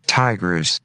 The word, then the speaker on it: Tigris: Is it Tigris or Tigris? Tigris River pronunciation. Not p- pollution. Oh man, is there pollution in it? Probably. Let's hear it. Is there going to be an ad? Tigris. Ti- Whoa. Tigris. I love that old voice.